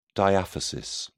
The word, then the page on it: diaphysis: British English